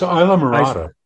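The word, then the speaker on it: Islamorada: It's Isla Morada. Is that how you say it? – It's Isla Morada.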